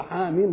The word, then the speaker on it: حم: فعرفنا حاميم تنطق كده زي ياسين وزي طه